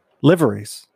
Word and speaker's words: liveries: Liveries. – Liveries? – It's not. It's liveries. Everybody corrects me. – I know, that's where liver, like the organ.